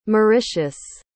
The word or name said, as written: Mauritius